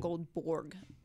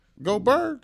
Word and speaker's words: Goldberg: It's not Goldborg. – Goldberg? – Yes.